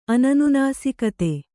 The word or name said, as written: ಅನನುನಾಸಿಕತೆ